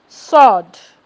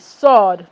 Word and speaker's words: sword: It's silent in the letter W there, or the wo cannot be found in letter W in words like answer. So it is silent there. Who, ho, sod, S-W-O-R-D, sod, no wo.